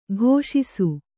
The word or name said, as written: ಘೋಷಿಸು